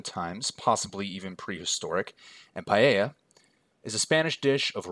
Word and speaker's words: paella: I hope you enjoy my pronunciation of paella. My wife made sure that it was correct.